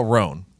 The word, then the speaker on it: Rhone: So I just want to get it out there right away that it's spelled R H O N E. – Okay. Yeah. – These guys. – Smart. – If you guys haven't heard about Roan, you're really missing out.